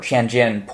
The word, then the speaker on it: Tianjin: or Tianjin port explosion, I think it's pronounced.